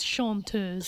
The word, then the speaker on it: chanteuse: What is chanteuse? – Chanteuse, you're pronouncing it correctly. I've been saying it the American way. I'm going to try to say it the correct way.